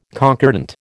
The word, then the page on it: concordant: American English